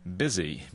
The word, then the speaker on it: busy: Yes, I said the E in the word pretty is pronounced with a short I. The O in women is pronounced with a short I. In what four-letter word is you pronounced as a short I? – Huh. It should be easy. What's the answer? – Busy. B-U-S-Y.